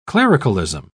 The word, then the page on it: clericalism: British English